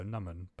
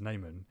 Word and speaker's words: Naaman: Or, yeah, like, you've been pronouncing my name how my family would pronounce it, so Naman, but my English name is Naaman, because it's just easier. Or when I play hockey, instead of that, I just say, just call me Nam.